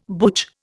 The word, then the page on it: butch: Indian